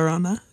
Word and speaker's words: La Llorona: And then I saw La Lorena. I think it's the pronunciation. La Llorona.